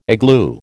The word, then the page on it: igloo: American English